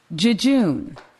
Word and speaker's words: jejune: 옛날에는 jejune이라고 읽지 않고요. 이거를 라틴어로 그대로 읽으면 어떻게 읽냐면은 여윈이라고 읽습니다. 어? 여윈이라고 읽는 거예요. 우리말로 읽으면. 우리나라 말은 그러니까 원래는 여위다. 여위는 뭐냐면 빈약한, 재미없는 예요. 그래서 그리스 사람이나 로마 사람들은 이걸 읽을 때 여윈 이렇게 읽어요. 그런데 이걸 영어식으로 읽게 되면은 영어에서는 예수도 지저스라고 하는 것처럼 발음을 이걸 쥐쥐 이렇게 읽기 때문에 쥐쥔 이렇게 된 거고요.